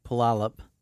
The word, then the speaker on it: Puyallup: Remember, Steve lives in Puyallup. Oh, I said it right that time.